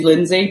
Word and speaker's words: Lindsay: my name's Lindsay, but somebody used to mispronounce it, and they ended up calling me Minzy, and then it got shortened to Minge.